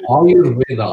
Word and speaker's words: Ayurveda: Ayurveda, Ayur means life and Veda means knowledge.